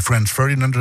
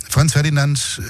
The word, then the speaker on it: Franz Ferdinand: Wird auch deutsch ausgesprochen, nicht irgendwie Franz Ferdinand oder so. Nein, Franz Ferdinand und Drummer, heute Geburtstag, deswegen musikalisches Kalenderblatt.